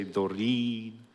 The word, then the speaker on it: Doreen: Doreen is my middle name. I don't use it, Doreen, with the American because it's like a girl name, Doreen. Especially if you say Doreen. It's a long E.